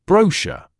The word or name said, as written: brochure